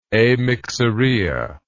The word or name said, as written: amyxorrhea